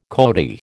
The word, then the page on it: coati: American English